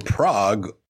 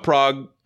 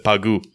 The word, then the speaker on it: Prague: And also fun anecdote about Prague, JJ wanted to pronounce it when she was asking me where I was or whatever, and she called it Pagu.